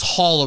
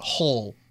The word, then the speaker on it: Hull: So, Evan Hull here, rounding out. I thought it was Hall originally, but Hull, H-U-L-L for him.